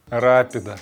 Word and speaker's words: Рапида: Рапида. Рапида, ударение правильно.